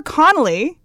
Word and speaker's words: Connelly: So Jennifer Connelly is three syllables.